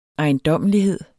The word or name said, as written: ejendommelighed